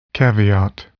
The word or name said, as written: caveat